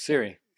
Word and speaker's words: Siri: Is that how you say it? Siri, or is it Alexa? – Siri.